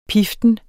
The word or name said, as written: piften